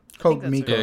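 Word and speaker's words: CodeMiko: They are also not cheap because Code Miku. is it, am I saying that right? Code Miku. Code Miko. Yeah, Code Miko.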